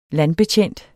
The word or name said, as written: landbetjent